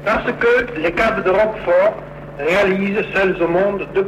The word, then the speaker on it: Roquefort: Alors, je vais le prononcer Roquefort pour faire plaisir à tous nos auditeurs aveyronnais. C'est un fromage qui, effectivement, n'a pas beaucoup changé.